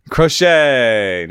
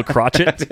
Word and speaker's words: Crochet: Garrett Crochet. – Crochet. Nice. Better than the other one, which rhymes with rocket. – you were going to go crotchet?